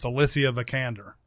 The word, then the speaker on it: Alicia Vikander: We've on this podcast, we have discussed how this name is pronounced many times. I finally found her variety thing where they ask Google questions and all that, and they always tear off the thing. And it's Alicia Vikander.